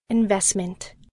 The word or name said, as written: investment